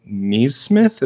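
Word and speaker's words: Nesmith: I guess Aaron Neesmith, is that how you pronounce his name? – Yeah.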